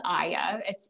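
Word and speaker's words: iaya: Yes, it's called Aya. It's spelled I-A-Y-A. A-Y-A is adolescent young adult for our listeners.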